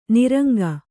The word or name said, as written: ನಿರಂಕ